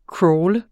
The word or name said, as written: crawle